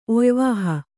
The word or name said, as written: ಒಯ್ವಾಹ